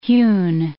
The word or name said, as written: hewn